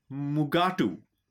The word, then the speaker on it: Mugato: So the name was changed in the script to Mugato. However, both Kelly and William Shatner then proceeded to pronounce that as Mugatu.